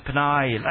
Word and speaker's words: Peña: Enrique Peña, you got to say the Peña, like, you know, some people, that's a correct pronunciation.